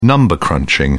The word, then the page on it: number crunching: British English